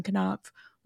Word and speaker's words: Knopf: And Jenna, I'm glad you read that instead of me because I did not know that the K in Knopf was not silent.